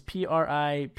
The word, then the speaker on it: Pripyat: And if you go through the town now, and I'm going to spell it out again, I think it's pronounced Pripyat. It's Pripyat.